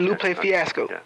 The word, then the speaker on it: Lupe Fiasco: There's always going to be a market for Talib Kweli, you know, for Common, for Lupe Fiasco. Did I pronounce his name right? – Yeah, you pronounced it Lupe Fiasco.